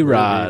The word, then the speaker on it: Rhod: Yeah, Ruby Rod. Ruby Rod. – Correct. Rod spelled R-H-O-D. – Yeah. – Silent H. You don't see that very often.